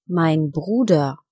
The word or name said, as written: Bruder